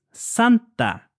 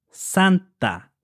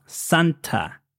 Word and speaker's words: Santa: And by the way, in the case of Santa, the pronunciation is Santa. The T in Spanish is pronounced between your teeth, Santa. Not Santa, you see?